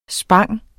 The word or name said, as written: spang